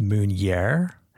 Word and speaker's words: meunière: It looks like the word manure, and it might actually mean that as well. Might mean the same thing, but it's actually called, or it's pronounced meunière.